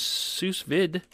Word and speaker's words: sous vide: First, we're going to start off with sous vide, right? – Sous vide, whatever, where you get to waterboard. – The D is silent.